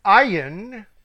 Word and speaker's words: Ayin: Number 16, Ayin, which is usually a silent letter. It's in there, but it's silent.